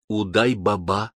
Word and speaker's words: Удай-Баба: Под стеной почему-то разместился на ночлег известный всему восточному рынку персонаж, носивший откровенно фальшивое имя Удай-Баба с ударением на последнем слоге.